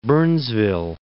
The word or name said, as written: Burnsville